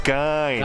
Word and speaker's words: Gein: They are both very loosely based on the same guy, as in Ed Gein is the correct way to say it, but everybody calls him Ed Gein. – Gein, right. – Yeah, right. But his name is pronounced Gein.